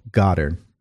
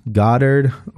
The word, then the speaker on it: Goddard: His name is Neville Goddard. N-E-V-I-L-L-E. Goddard or Goddard. Some people pronounce it differently. G-O-D-D-A-R-D.